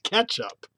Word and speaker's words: catsup: The Merriam-Webster has catsup, C-A-T-S-U-P. The first pronunciation and the one that you can actually hear is ketchup.